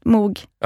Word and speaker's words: Moog: Alltså man säger Moog, men jag säger konsekvent Moog.